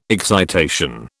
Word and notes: excitation: British English

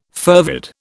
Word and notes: fervid: British English

